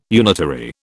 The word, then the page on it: unitary: British English